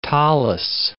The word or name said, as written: tallith